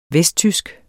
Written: VESTTYSKE

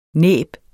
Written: næb

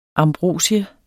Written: ambrosia

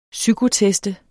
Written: psykotest

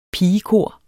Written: pigekor

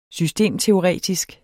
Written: systemteoretisk

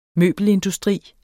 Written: møbelindustri

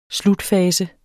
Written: slutfase

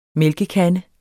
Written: mælkekande

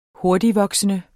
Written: hurtigtvoksende

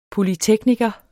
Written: polytekniker

